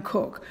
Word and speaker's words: Coke: My father was a fifth Earl of Leicester, so I became Lady Anne Cook. It's spelled Coke, but it's pronounced Cook.